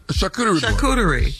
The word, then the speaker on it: charcuterie: Charcuterie.